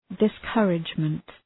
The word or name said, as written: discouragement